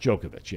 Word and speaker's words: Djokovic: Djokovic, yeah.